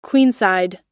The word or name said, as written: queenside